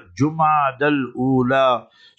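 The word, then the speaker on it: Jumada al-Ula: So pronounce the months correctly as well, Jumad al-Ula.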